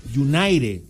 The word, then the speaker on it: United: pero Veraguas United se pronuncia en inglés, United, no, una palabra en español y una en inglés.